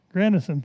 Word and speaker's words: Grandison: And no other person has influenced the subject of revival in America like Charles Grandson, Grandison, how do you say that? Grandison Finney, okay.